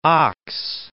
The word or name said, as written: ox